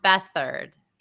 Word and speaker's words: Beathard: How do you pronounce his last name? – I'm sorry, I was typing. Muted. Beathard. – Beathard. Oh, Beathard.